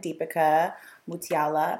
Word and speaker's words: Deepica Mutyala: My friend Deepica Mutyala.